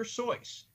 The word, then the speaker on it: Seuss: Yes, it's Dr. Seuss.